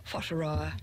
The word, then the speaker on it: Whataroa: Whataroa. – Whataroa, yeah, Whataroa.